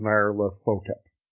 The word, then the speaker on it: Nyarlathotep: Nyarlathotep.